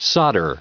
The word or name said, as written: solder